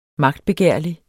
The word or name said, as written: magtbegærlig